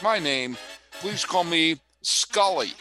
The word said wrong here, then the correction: Sully: Sully, folks.